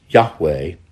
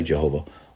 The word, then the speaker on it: Yahweh: In early English translations, it was written out Jehovah, which we know is not the exact way it should be pronounced. Nevertheless, it's Yahweh, or if you want, you can say Jehovah.